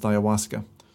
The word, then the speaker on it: ayahuasca: It's spelled AYAHUASKA and pronounced ayahuasca.